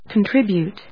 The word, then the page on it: contribute: kəntríbjʊt, kˈɔntrɪbjùːt